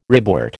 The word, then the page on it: ribwort: American English